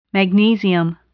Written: magnesium

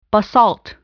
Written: basalt

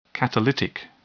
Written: catalytic

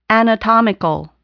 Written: anatomical

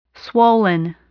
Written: swollen

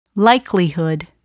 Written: likelihood

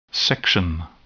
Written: section

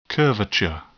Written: curvature

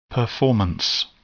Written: performance

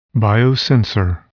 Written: biosensor

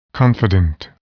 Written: confident

